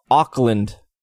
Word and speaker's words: Auckland: It's pronounced Auckland. Alex. – I don't think Auckland is a hard one to pronounce. – Yeah, but it's L-A-N-D, so you think of... At least I think of saying land, so I would say Auckland.